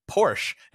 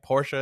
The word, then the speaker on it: Porsche: But I am American, and we say Porsche in America for some reason. But is it Porsche, right? Porsche is the correct way to say that, I believe.